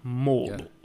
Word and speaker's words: Mordor: Mordor. And you've got... – I really liked that little, your pronunciation there. You got the R's right. – Mordor.